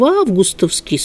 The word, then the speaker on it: августовский: Ну, я-то думаю, что здесь спокойно проходит и ударение нормативное, как мне кажется, и в августовский свод из мрамора.